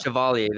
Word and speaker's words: Chevalier: Chevalier, I think they said on the Chevalier, Chevalier, Chevalier, something like that. – Yeah. Chevalier, there you go. That's what it was on the yeah.